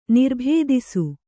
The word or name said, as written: ನಿರ್ಭೇದಿಸು